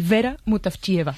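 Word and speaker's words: Vera Mutavcheva (Вера Мутафчиева): Ayúdanos a pronunciar los autores. – A ver, Georgi Gospodinov, sí. Zdravka Eftimova y Vera Mutavcheva.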